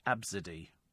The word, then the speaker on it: Abcde: What on earth made you come up with A, B, C, D, E, pronounced Absidy?